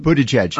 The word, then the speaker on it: Buttigieg: Buttigieg.